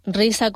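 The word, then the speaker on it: RIZA: Para nuestros oyentes, RISA con Z, aquí en Canarias pronunciamos mucho la S, para especificarlo.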